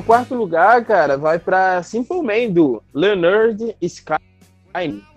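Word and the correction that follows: Lynyrd Skynyrd: Isso a pronúncia se diz essa no álbum, né? Que o nome do álbum é Se Pronuncia Leonard Sky Nerd. O nome do álbum da banda é esse.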